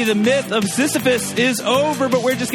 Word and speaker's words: Sisyphus: Sisyphus.